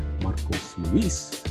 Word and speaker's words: Marcos Luis: And you can follow me at Marcos Luis, M-A-R-C-O-S-L-U-I-S.